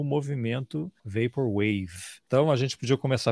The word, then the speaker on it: Vaporwave: Primeiro, é uma coisa certa, a sua pronúncia está completamente correta, Vaporwave, mas por hábito, ou por vício de linguagem, eu provavelmente vou acabar chamando de Vaporwave uma hora.